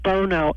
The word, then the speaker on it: Bono: you pronounce it Bono, and it was Sonny.